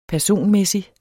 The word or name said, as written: personmæssig